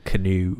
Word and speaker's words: Keanu: Keanu. – Keanu? Is it Keanu? – Keanu Reeves. – Maybe that's what I should name my baby. – Keanu. No, don't name him Keanu.